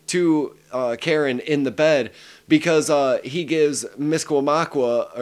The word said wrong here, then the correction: Misquamacus: or I think I said that wrong, but Misquamacus, I'm sorry. – Yeah, Misquamacus.